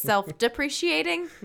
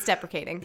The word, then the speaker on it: deprecating: It's not self-appreciating, so it's self-depreciating? No. It's deprecating.